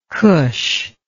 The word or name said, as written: cush